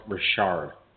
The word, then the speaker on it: Richard: I don't like rostering a player whose last name is Richard, pronounced Richard. – Like Maurice Rocket Richard?